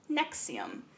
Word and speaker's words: NXIVM: So, I'm telling the story of Nexium. It is spelled N X I V M, mm-hmm. all caps. It almost it looks like Roman numerals.